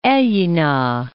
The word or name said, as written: Aiyina